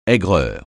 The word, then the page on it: aigreur: français